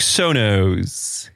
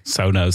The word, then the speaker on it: Sonos: En natuurlijk Sonos. – Sonos. – Zeg ik dat goed zo? – Ja, ja zeker.